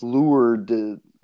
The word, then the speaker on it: Lourdes: Our Lady of Lourdes. – Lourdes. It's French.